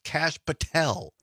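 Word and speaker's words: Kash Patel: Oh, Donna says it's pronounced Cash Patel.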